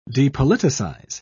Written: depoliticize

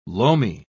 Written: loamy

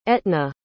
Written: etna

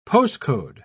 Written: postcode

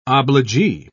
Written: obligee

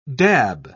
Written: dab

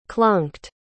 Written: clunked

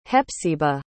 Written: Hepsiba

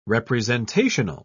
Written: representational

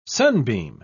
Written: sunbeam

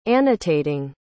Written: annotating

